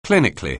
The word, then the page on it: clinically: anglais